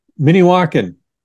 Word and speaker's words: Minnewaukan: Minnewaukan? – Minnewaukan.